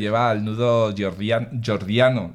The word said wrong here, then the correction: Gordiano: ¿lo he dicho bien? Gordiano. – Gordiano, sí.